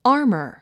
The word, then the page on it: armor: ɑ’ː r mə r; アーマー